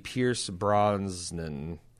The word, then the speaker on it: Brosnan: which of the Pierce Brosnan, is that how you say it? – That is.